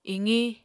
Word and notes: iqiñ: Apatani

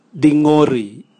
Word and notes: dingori: Raga

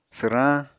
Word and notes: swrañ: apatani

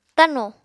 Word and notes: tano: Apatani

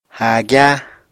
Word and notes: háagyá: apatani

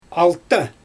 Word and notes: alta: Xyzyl

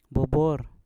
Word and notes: bubwor: Mpur

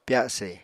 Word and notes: pyase: Apatani

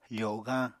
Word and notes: fogañ: apatani

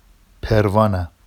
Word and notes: pervɑna: Vafsi

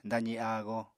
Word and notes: dazi áago: apatani

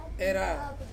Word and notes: ra: Ansus

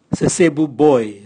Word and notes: sesebu boe: Raga

